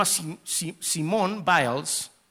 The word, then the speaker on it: Simone Biles: Ella se llama Simone Biles, así es como se pronuncia.